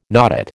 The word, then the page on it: notate: American English